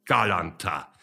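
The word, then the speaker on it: Galánta: Und das Aksau auf dem zweiten A, der Aksau, der betrifft nicht die Betonung, sondern die Aussprache des Buchstabens. Also Tänze aus Galanta, wenn Sie mit Ungarn guten Kontakt wollen.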